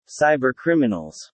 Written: cybercriminals

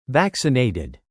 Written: vaccinated

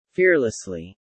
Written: fearlessly

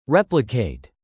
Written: replicate

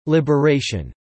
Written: liberation

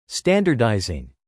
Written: standardising

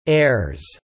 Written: heirs

